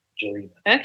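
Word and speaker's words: Gjelina: It's spelled G-G-J. – Uh huh. – E-L-I-N-A, Jelena.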